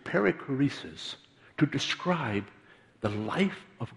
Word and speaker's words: perichoresis: Did you know, there's a very big word, and I had to check the pronunciation. It's perichoresis, or perichoresis.